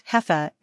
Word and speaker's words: jefa: One day, one of the guys called me Jefa, pronounced Hefa and meaning boss lady.